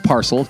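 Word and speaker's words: PARXL: No, it's pronounced parcel, just like the English word, but like so many companies, we tried to create a unique brand.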